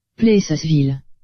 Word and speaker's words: Plessisville: Plessisville. – Ah, il y a toujours les dedans. – Il y a toujours l'S.